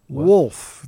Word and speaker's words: wolf: And I also mispronounced the word woof. Wolf. There's supposed to be an L in wolf.